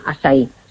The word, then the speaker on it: açaí: Si lo queréis buscar escrito, que se escribe A con la cedilla, como si fuera esta portuguesa, y se llama eso acai orgánico, y se pronuncia açaí.